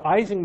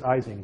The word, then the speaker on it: Ising: You know, Americans pronounce it Ising, but Germans pronounce it Ising. And so if you go to Germany and say Ising, they'll growl at you.